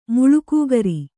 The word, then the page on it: ಮುಳ್ಳುಕೂಗರಿ: muḷḷu kūgari